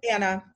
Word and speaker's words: Annah: Annah. It's okay. Confusing with the H on the end.